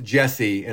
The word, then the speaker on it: DJESSE: Jesse is pronounced Jesse, and it's a reference to his initials, JC. The D is silent.